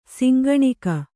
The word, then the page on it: ಶಂಖನಖ: Kannada